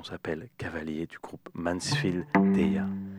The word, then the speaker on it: Tya: Moi, je dis T-Y-A parce qu'on va le prononcer à la française. – Allez, ah, voilà. Ça me va.